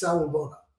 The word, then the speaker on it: Sawubona: So then he said to me, he said, you know, I'm from South Africa, and in the Zulu culture, there's a word, and it's pronounced Sawubona, Sawubona.